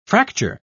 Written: fracture

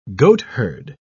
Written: goatherd

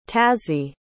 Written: taszi

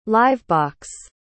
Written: live-box

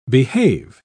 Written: behave

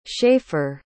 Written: shaffer

